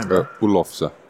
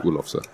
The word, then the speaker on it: Oelofse: Oelofse. – Oelofse. – Oelofse, yeah.